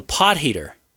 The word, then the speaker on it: Potgieter: Pot jeeter. – He's South African, and it's crucial to use the he sound, a soft G, rather than a hard G. So pot heater.